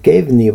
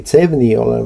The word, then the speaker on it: CEVNI: Noh, õppekava selles mõttes, et Reinil ja ka kohati Toonaul on omad kohalikud reeglid, mida tuleb tunda lisaks siis Kevni või Cevni, kes kuidas hääldab, see on siis Euroopa siseveeteede koodeks.